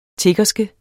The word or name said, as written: tiggerske